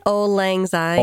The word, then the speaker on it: Auld Lang Syne: Auld Lang Syne. – Auld Lang Syne.